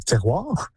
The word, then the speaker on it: terroir: And a sense of, I don't know how you say this in English, but terroir. – And that's exactly how you say it.